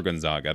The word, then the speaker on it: Gonzaga: Like, did you commit like to Gonzaga after is it Gonzaga or Gonzaga? I don't want to butcher it. – Gonzaga. You did it right.